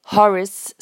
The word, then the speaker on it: Horace: en anglais Horace Slughorn. Vous avez remarqué que le A de Horace, je l'ai prononcé presque comme un I.